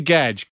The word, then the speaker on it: Gedge: Peter Gedge rhymes with Nita Hedge.